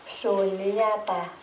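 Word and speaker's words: śūnyatā: Chữ S có dấu sắc, mình đọc là chữ sờ. Sunyata.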